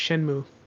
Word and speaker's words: Shenmue: I think it's just Shenmue. – Shenmue. – Because there's a video game called Shenmue. – Yeah, it's based on the classic Sega video game. – Okay, so it's Shenmue.